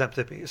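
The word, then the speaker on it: Xanthippe: But she was such a shrew that there's a word in the dictionary, uppercase X, spelled with X, but it's pronounced Xantippe.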